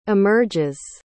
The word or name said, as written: emerges